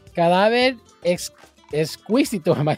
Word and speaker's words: Cadáver Exquisito: And so here is Vero Perez's brand new hit, Cadáver Exquisito. I'm butchering that. Is that right? Cadáver Exquisito. – Exquisito.